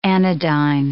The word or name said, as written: anodyne